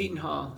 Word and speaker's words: Seton Hall: Seton Hall.